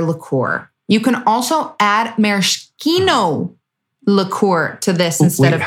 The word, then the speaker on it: maraschino: How'd you say it? Say it again. – Maraschino, which Uncle Brad told me how to say it, right? About. – I love it. – You know what? – All right. – I say it wrong once, you correct me, I'll never say it wrong again.